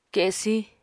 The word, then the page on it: kesí: Apatani